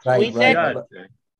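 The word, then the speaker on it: Świątek: Swiatek. – Swiatek.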